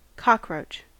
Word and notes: cockroach: American English

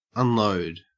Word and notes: unload: Australian English

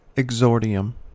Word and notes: exordium: American English

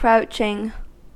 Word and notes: crouching: American English

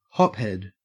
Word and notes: hophead: Australian English

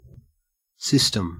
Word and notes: system: Australian English

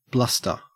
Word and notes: bluster: Australian English

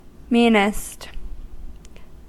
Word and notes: meanest: American English

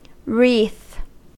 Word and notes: wreath: American English

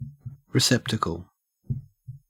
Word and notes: receptacle: Australian English

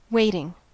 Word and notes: waiting: American English

